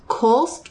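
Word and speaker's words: Kölsch: So this one, I think it's pronounced Kolsch. It's K-O-L-S-C-H, if I'm wrong.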